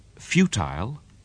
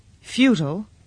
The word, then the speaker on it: futile: That word, futile, futile, is another word ending in ILE, which is pronounced differently in British and American English.